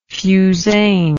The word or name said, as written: fusain